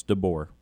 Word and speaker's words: DeBoer: Yes, DeBoer.